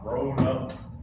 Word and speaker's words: Roanoke: Roanoke.